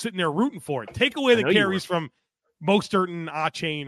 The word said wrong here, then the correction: Achane: Achane, come on. – Achane. I corrected myself within 1 second, so pronunciation police put the cuffs away.